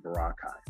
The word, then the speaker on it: Boracay: Boracay. It looks like Boracay, but you pronounce it Boracay.